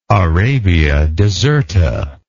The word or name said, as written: arabia deserta